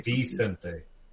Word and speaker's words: Vicente: It's not Vin, too. It's V-I-C. Which is Vicente. – Right, not Vincent.